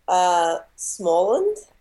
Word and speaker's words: Småland: Yeah, so my great-grandma is from Sweden, and she is from Småland.